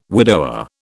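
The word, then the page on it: widowhood: British English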